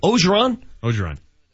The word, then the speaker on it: Orgeron: This, if there's ever been a perfect coach for LSU, it's that Ed Orgeron. Is that how you pronounce his name? – Orgeron.